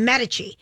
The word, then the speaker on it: Medici: Am I pronouncing it right? Medici. – Mm-hmm. – Medici.